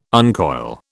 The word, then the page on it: uncoil: British English